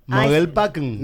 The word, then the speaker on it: Mogelpackung: En alemán se pronuncia así. Mogelpackung.